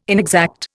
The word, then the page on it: inexact: Indian